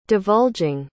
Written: divulging

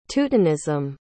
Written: teutonism

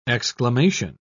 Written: exclamation

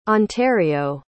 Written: ontario

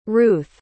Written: ruthe

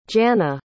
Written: Janna

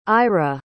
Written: ayra

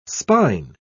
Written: spine

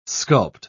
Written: sculpt

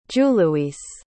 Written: juluis

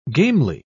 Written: gamely

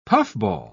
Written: puffball

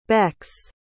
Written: becks

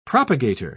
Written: propagator

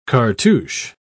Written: cartouche

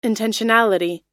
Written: intentionality

